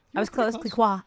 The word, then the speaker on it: Clicquot: Can you pronounce the second part of that? – Clicquot.